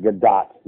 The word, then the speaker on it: Gadot: She said her name is pronounced Gadot, not Godot or whatever.